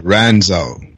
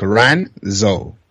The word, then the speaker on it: Ranzo: Yeah, Renzo. – Say it again? – So R-A-N-Z-O, so like Renzo.